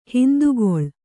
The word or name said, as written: ಹಿಂದುಗಳೆ